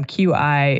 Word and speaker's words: qi: And so CHI is kind of the old system. QI is part of the new system that Chinese speakers are like, wait a second, that's actually how we would pronounce this, not the way that you know, English people think that it should be pronounced.